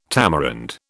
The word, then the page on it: tamarind: British English